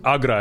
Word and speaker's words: Agra: what's your favorite part of going to Bagra? You know? – And well, it's Agra, actually. – Agra, whatever. – The B is silent. – Yeah, the B, no B.